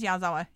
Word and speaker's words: Yazawa: No, it is Yazawa.